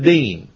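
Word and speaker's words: deen (דין): Strive is the Hebrew word deen. D-E-E-N, if you want to pronounce it.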